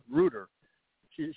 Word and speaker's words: Reuters: Reuter. She, she's talking on, uh, you know, very highly regarded news. right. How do you pronounce it? Router? Reuter? Reuter? Reuters, yeah.